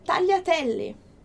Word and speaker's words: tagliatelle: Por exemplo, tagliatelle.